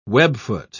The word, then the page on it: webfoot: British English